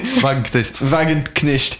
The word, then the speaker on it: Wagenknecht: Wagenknecht, je sais pas si je prononce bien. – Wagenknecht.